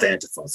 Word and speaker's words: Antifa: it's pronounced Antifa,